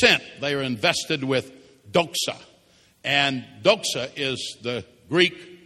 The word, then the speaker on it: doxa: Well, if you read the context of it, to be sent means to be invested with a Greek word that in English we would pronounce doxa, D O X A.